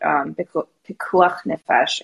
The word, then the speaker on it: Pikuach Nefesh: In the spirit of that, I definitely want to talk a little bit about Pikuach Nefesh. I can never say that right. But I think I just did.